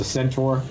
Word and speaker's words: centaur: So I love the pictures you sent me of Trump as a half beast, half man, as a centaur.